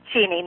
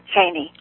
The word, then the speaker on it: Cheney: And that's how you pronounce it, Cheney, not Cheney.